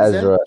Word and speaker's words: Ezra: Play as Barry Allen in the Flash, but Irza, Irza, I think that's how you say his name, right? Irza, Irza? – Ezra, yeah, Ezra Miller. Ezra yeah. Miller.